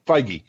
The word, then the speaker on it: Feige: That Feige basically, am I pronouncing his name right? – Feige.